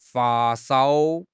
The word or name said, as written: fa1 shao1